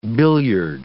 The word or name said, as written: billiard